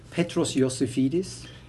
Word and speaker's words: Petros Iosifidis: You know, the in Greek, the I is pronounced as E. So it is Iosifidis. But most of the English pronounce me Iosifidis or something, which is kind of Wi-Fi, high fidelity or something.